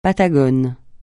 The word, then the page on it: patagon: francais